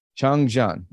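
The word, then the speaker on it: 长江: Changjiang. Changjiang.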